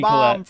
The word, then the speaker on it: Collette: And I also have, I'm going to pronounce this wrong, Toni Collette? The bomb Collette.